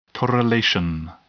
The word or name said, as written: correlation